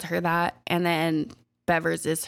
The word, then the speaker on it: Bevers: Missy Bevers is how I think it's pronounced. I've heard it both. I've heard it Bevers or Beavers, but it's spelled like Bevers. So I'm just going to say Bevers.